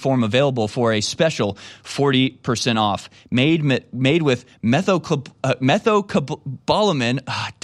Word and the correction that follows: methylcobalamin: Darn it, I thought I could do it. I only know how to pronounce that because methylcobalamin. – Methocobalamin. – Methylcobalamin. – Methylcobalamin.